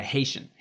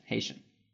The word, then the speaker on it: Haitian: For people who know how to pronounce words correctly, that's half Haitian, half Japanese. – Really? – Yeah, Haitian. Haitian.